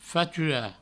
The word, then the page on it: faturar: fatura; Provençal